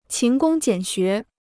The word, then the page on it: 勤工俭学: qín gōng jiǎn xué